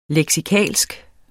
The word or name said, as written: leksikalsk